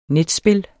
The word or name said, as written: netspil